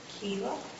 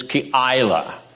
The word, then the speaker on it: Keilah: Nice try at pronunciation. The proper Hebrew pronunciation is Keilah. Keilah. All right, that may be a little hard for an Anglo Saxon or an Western person to get down, but you have to get a little Hebrew Semitism into your pronunciation and the rhythm of your speech.